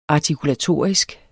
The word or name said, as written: artikulatorisk